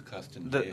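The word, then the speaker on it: Castañeda: So our first vehicle, is the Salud Castaneda. – Pronounce the tilde. – Nieda. – And the Salud Castaneda.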